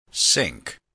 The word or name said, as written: sink